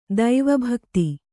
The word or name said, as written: ದೈವಭಕ್ತಿ